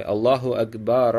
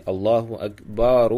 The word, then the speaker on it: Аллаху акбар: То есть произносить ра, первое ра, вот акбар в конце, да, произносить с фатхой. Аллаху акбар, Аллаху так. Вот. Но тоже Ибн Хаджар, рахимахуллах, пишет, что часть ученых сказала, что лучше с даммой.